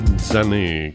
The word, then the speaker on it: Zhané: Madrid de los Austrias, Más Amor, Arruado, Trombeta y ahora Zane, como dicen ellas. Request Line, qué bien suena este tema. Zanay, como dicen, se pronuncia así.